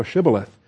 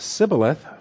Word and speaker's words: Shibboleth: If he said, no, then they would say to him, say now Shibboleth. But if he said Sibboleth, for he could not pronounce it correctly, then they seized him and killed him.